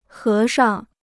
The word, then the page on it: 和尚: hé shang